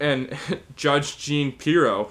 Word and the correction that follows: Jeanine Pirro: who I think, am I saying that right? Is it Jeanine Pirro? I'm not exactly sure. – Oh, it's Judge Jeanine. – Yeah, Judge Jeanine Pirro was sort of defending the, you know, justice system, saying that we have to accept this verdict.